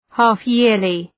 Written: half-yearly

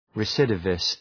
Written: recidivist

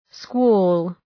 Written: squall

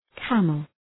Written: camel

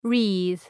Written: wreathe

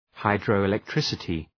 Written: hydroelectricity

